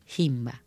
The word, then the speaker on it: Himba: Les recomiendo a nuestros oyentes que busquen eh, a la tribu Himba. Se escribe H-I-M-B larga A.